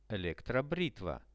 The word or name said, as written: electrobritva